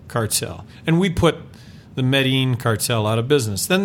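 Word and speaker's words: Medellín: We had Medellin cartel. Uh-huh. I'm not going to probably pronounce this right. – Medellin, yes.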